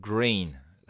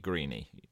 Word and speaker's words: Greene: Although it's got an E on the end. It's not going to be Greeny. I know that. It's Green. Robert Greene.